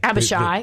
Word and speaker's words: Abishai: To take Abishai, or is that how you pronounce it? – Abishai. It's actually on the line.